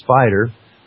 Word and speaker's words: SPDR: the SPDR Gold Trust, pronounced SPIDER, holding 1,299 metric tons of gold valued at $57 billion, can come into these markets and just cause mayhem overnight, in a nanosecond.